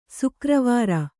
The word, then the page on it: ಸಾಗರವರಿ: sāgaravri